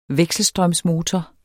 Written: vekselstrømsmotor

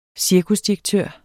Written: cirkusdirektør

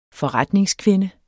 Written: forretningskvinde

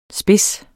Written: spids

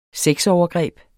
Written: sexovergreb

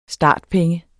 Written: startpenge

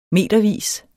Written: metervis

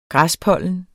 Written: græspollen

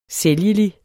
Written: sælgelig

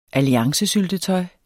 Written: alliancesyltetøj

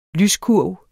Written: lyskurv

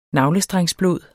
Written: navlestrengsblod

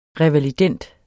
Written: revalidend

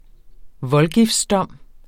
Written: Voldgiftsdom

